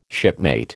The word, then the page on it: shipmate: American English